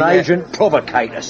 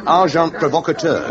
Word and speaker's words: agent provocateur: And an agent provocateur, Sergeant. – Amazing, Thackeray. The pronunciation, however, is agent provocateur.